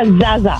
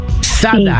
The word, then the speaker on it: zaza: Sí, so se pronuncia zaza, es Z-A-Z-A, zaza. – Zada.